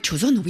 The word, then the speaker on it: Joseon: Est-ce qu'il y a des traces de la fameuse dynastie, je ne sais pas comment on prononce ça, Joseon ? – Donc la période Joseon, oui, bien sûr.